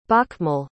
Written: bokmål

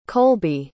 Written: kolby

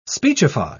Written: speechify

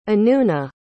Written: anoona

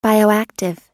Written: bioactive

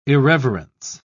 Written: irreverence

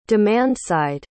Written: demand-side

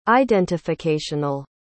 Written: identificational

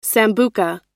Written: sambuca

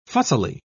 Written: fussily

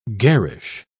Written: garish

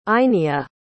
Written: einya

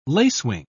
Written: lacewing

